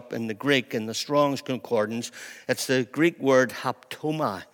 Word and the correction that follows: haptomai: All right, I'm not sure I'm pronouncing that very well, but it's haptomai.